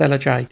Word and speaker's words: Ellujai: I think it's pronounced Ella J. It's actually spelled E-L-L-U-J-A-I. But it seems to be Ella J because I've just checked out his Instagram.